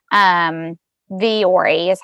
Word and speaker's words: Vuori: So it's a brand of clothes called Vuori. It's spelled V-U-O-R-I. Vuori is how you say it.